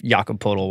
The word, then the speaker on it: Jakob Poeltl: So it's really what we're getting down to is this Kawhi for DeMar swap, ultimately, even if Jakob Poeltl winds up being the next, I don't know, who could he be? – Flawless pronunciation, by the way.